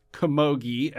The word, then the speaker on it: camogie: And then the final one on the list, camogie. And again, let me know if I'm pronouncing that correctly. – Yeah, it's correct.